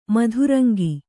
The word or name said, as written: ಮದರಂಗ